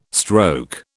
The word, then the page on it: stroke: British English